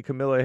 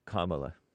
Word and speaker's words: Kamala: And he spelled it Camilla. That's why I pronounced it that way. – How would you have not pronounced it otherwise? – The proper way. – What's that? – Kamala. – Oh, Lord. That's her name? – Mm-hmm.